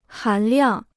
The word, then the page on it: 含量: hán liàng